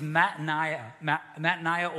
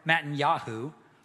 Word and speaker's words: Mattaniah: His name was Mattaniah or Mattanyahu. Is another pronunciation, and he changes his name to Zedekiah.